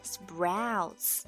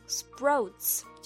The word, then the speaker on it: sprouts: 注意 sprouts 中间的 ow 就像老虎张开嘴 ow 的声音一样 sprouts 一定要发饱满。而有的小朋友和家长呢，会读成 sprouts，就是不自觉的发音不饱满，而且是发成了 o 的音，而不是 ow 的音。